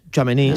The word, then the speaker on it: Tchouaméni: ¿Cómo se pronuncia? ¿Tchouaméni? – Tchouaméni, sí. Tchouaméni.